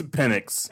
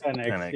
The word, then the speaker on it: Penix: It's Penix. – Penix.